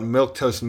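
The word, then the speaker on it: milquetoast: I don't want milk toast milk. I want milk that could milk me back. – Milk toast is surely the strangest word I've ever seen. Because it's spelled M-I-L-Q-U-E, toast.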